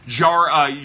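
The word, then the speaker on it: Yariel: I do think Jari, it's the Y is pronounced like a J. It's like Jariel Gonzalez.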